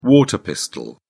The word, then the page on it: water pistol: British English